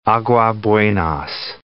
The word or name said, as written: Aguas Buenas